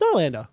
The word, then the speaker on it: Nylander: Do you pronounce it Nylander? – It's Nylander.